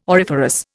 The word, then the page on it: auriferous: Indian